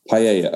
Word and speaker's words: paella: You were cooking with, I'm going to see if I say this right, a paella? – Yeah, paella.